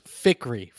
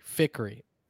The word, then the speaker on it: Fikri: Last name is Fikri. Fikri. – Fikri. – It's actually easier than my first name, I feel like. Yeah, Fikri. It's sounds like it's spelled, you know? – Okay.